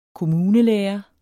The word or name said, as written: kommunelærer